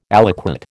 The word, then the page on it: aliquot: American English